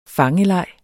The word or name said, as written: fangeleg